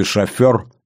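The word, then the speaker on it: шофёр: К примеру, перед войной было принято говорить «кофе» вместо привычного «кофе» или ставить ударение в слове «шофер» на первый слог, ну и так далее.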